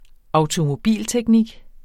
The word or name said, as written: automobilteknik